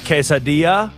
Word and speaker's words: quesadilla: Last night I had a quesadilla.